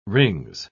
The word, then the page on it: rings: British English